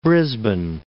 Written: Brisbane

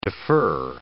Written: defer

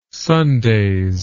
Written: Sundays

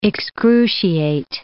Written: excruciate

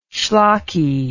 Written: shlocky

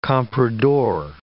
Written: comprador

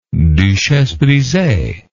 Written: duchesse brisee

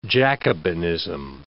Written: Jacobinism

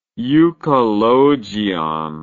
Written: euchologion

